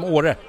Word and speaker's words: Åre: The Mirabelle from Åre. – Yes, Åre.